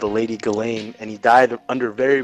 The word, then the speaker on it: Ghislaine: How the fuck do you pronounce that lady's name? – Ghislaine Maxwell. – Ghislaine? – Ghislaine, yeah.